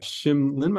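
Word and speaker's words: Shin Lim: Shim Lin, am I saying that correctly? – Mm-hmm.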